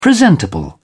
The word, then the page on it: presentable: British English